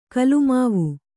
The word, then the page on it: ಗುಲಿಮಾವು: gulimāmu